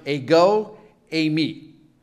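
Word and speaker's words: ego, eimi (ἐγώ εἰμι): It's pronounced ego, eimi.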